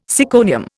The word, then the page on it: syconium: Indian